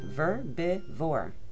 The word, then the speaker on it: verbivore: It is pronounced verbivore. It is spelled V-E-R-B-I-V-O-R-E.